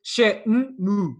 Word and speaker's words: Shenmue: Eh, ah, pois, pois, eh, pronto, isso, é, isso aí eu só te posso dizer qual é a pronúncia em japonês, porque é da forma como, é, como a palavra é escrita no, na caixa do jogo, em todo o lado, é, she, é, é, é literalmente shenmu shenmu só isso Agora, como a pronúncia chinesa, não, não faço a mínima okay.